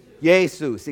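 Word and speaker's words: Jesús: how do they pronounce it? Jesus, exactly right.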